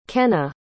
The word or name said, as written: kenna